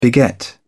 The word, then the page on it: beget: British English